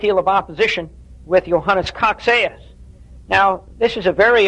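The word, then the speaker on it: Cocceius: So we need to investigate a bit Johannes Coxeus. That's C-O-C-C-E-I-U-S, but it's pronounced Coxeus, who lived from 1603 to 1669.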